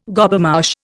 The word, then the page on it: Gobemouche: Indian